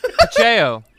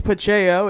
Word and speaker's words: Pacheco: Pacheco. – Pacheco. – Pacheco. – Wait, it's Pacheco. It's not a hard C.